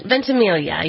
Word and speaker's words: Ventimiglia: So what's going on with Mandy Moore and, I've never pronounced this guy's name right, Milo Ventimiglia? Is that right? Am I close? I think I am pretty close. – Ventimiglia, yeah.